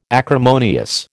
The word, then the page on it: acrimonious: American English